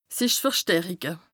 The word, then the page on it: sich verstärike: Bas-Rhin; Strasbourg